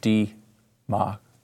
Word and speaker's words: democracy: First of all, democracy. D-ma-cra-cy. Four syllables. Not democracy. Say the whole word. Just get through it. It's not that long. It's four syllables.